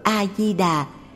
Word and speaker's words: A-di-đà: Chữ A trong danh hiệu Đức Phật A-di-đà phải đọc là A chứ không phải là Ơ.